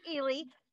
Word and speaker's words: Ehle: Yeah, you pronounce it Ehle, but it's a tricky name, so I answer to anything.